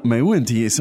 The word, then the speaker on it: 没问题: Now, 没问题 is something that you hear all the time.